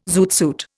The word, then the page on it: zoot suit: Indian